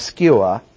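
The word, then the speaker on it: Sceva: and the seven sons of Skua, E-V is E-U in Greek, a diphthong, Skua, accent on the last syllable.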